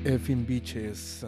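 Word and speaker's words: Fokin Biches: The name of the band is Las Effin, but they spell it as F-O-K-I-N, and then bitches, not, you know, that word, but B-I-C-H-E-S. And the song is called Somos in Rocknet at KXSF. – Rocknet. Yeah. – From Irapuato, Guanajuato, Mexico, those are las effing bitches, and that's how you pronounce it, actually.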